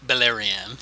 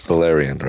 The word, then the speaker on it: Beleriand: This was the second battle of the Wars of Beleriand. I don't think I said that right. Beleriand. – Beleriand, right.